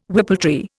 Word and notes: whippletree: Indian

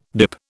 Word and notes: DIP: British English

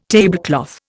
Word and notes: tablecloth: Indian

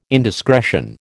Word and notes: indiscretion: American English

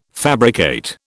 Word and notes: fabricate: British English

